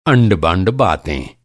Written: अंड-बंड

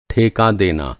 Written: ठेका

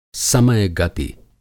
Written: समय गति

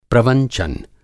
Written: प्रवंचन

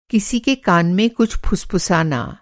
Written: फुसफुसाना

